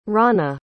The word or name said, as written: rawna